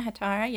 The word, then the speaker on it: hetaira: Is that how you say it? Hetera? Yeah, Hetaira, yeah.